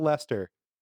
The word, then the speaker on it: Leicester: Steve, spell Lester as in the town of. – L-E-I-C-E-S-T-E-R. – See? Welcome to New England. We just chuck syllables in there and then pretend they don't exist.